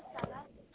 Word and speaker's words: Sana: No, this is Sana. Sana? How do you spell that? It's a beautiful name. – S-A-N-A.